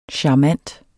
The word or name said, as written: charmant